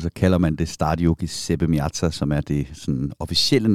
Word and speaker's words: Giuseppe Meazza: Vi åbner denne uges udsendelse med Liverpools europæiske udflugt til Giuseppe Meazza, hvor de første af to opgør mod Inter Milan altså skulle spilles. Var det udtalt rigtigt? Ja, det tror jeg.